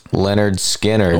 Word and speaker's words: Lynyrd Skynyrd: I've got kind of the self-titled album, pronounced Lynyrd Skynyrd.